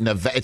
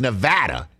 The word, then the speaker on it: Nevada: It's not Nevada, it's Nevada. You call Oregon.